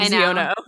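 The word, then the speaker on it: Xiono: Also, tell her I said hello, Kaz, Ziana, Ziano. – Oh my God, Caitlin, you got to learn how to say his name, Ziano.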